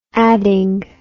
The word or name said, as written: adding